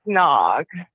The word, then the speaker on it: Nag: like the cobra's name is N-A-G, Nag. Mm-hmm. It's kind of funny, yeah. Like Nag's head, but it's pronounced Nog.